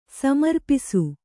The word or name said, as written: ಸಮರ್ಪಿಸು